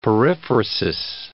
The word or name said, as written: periphrasis